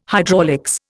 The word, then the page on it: hydraulics: Indian